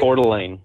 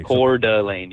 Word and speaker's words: Coeur d'Alene: Coeur d'Alene. How do you say that lake? I mean, is I get it right? – Coeur d'Alene. – Okay. – Coeur d'Alene. Yeah.